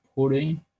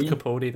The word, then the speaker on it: Capaldi: Or Capaldi. How are we going to pronounce it? I hear people say Capaldi and Capaldi. – I always say Capaldi. – Let's go with Capaldi then. I like it's more British, I think, in a way.